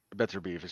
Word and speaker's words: Beterbiev: Beterbiev, excuse me. I started pronouncing it wrong, heard it pronounced correctly, and I'm trying to correct myself now as I go.